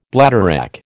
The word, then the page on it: bladderwrack: American English